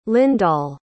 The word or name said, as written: lyndall